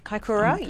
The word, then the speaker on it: Kaikoura: I can't pronounce it, Kaikoura. – Kaikoura, yes.